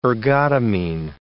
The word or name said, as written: ergotamine